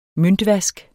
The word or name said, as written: møntvask